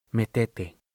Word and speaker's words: metete: Metete. It had the Argentine pronunciation, with the accent on the middle syllable. Me-te-te.